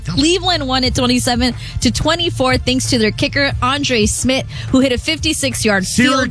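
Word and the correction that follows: Szmyt: Is it Schmidt? – Schmidt, yes. It's spelled S-Z-Y-M-Y.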